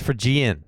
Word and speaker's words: Phrygian: P-H-R-Y-G-I-A-N. – P-H-R-A-Y. – P-H-R-Y. – Fry. – Phrygian, Phrygian. That's the dominant, that's the scale.